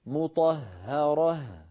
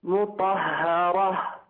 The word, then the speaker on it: مطهرة: مطهرة، رقق الهاءات. – مطهرة، الهاء الأخيرة عم تطلع معك مفخمة شوي، أعد فمك عند نطقها إلى الوضعه الطبيعي. مطهرة. – مطهرة. – أيوة هذا المطلوب، هذا المطلوب تماما.